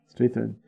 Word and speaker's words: Strathairn: Strathairn.